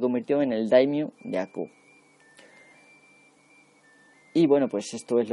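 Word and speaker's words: Akō: Por azares del destino, digamos, el dominio de Aku, o Aku, bueno, se pronuncia Aku, pero bueno, igual lo habéis visto por ahí como Aku, se le había concedido a su abuelo, Naganao, con unos ingresos de 50.000 koku.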